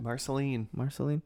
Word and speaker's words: Marceline: Marceline.